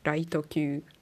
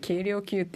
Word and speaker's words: 軽量級: ライト級ライト級だよ間違えて軽量級って読んじゃったよ漢字で軽量級って書いてあるけどライト級って読まないといけないんですよ